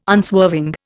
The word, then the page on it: unswerving: Indian